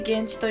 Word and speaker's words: 言質: 現地